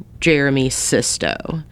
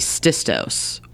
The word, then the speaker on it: Jeremy Sisto: Should I say at this point that sometimes you say his name the right way, Jeremy Sisto? And then sometimes you say Jeremy Sistos or Sistos's.